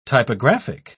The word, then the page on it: typographic: British English